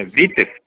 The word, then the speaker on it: Witthoeft: É Witthoeft. – Isso, esse mesmo. Como é que se pronuncia? – É Witthoeft.